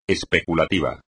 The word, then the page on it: especulativo: espagnol